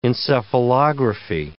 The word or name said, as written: encephalography